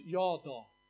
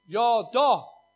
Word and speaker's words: yada: And the word in Hebrew is yada, or the way you pronounce it is yada. Truly, that's how you pronounce it. Yada.